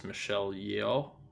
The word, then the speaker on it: Michelle Yeoh: And just for the viewers, the actress that we're referring to is Michelle Yeoh. I believe that's how you say her name.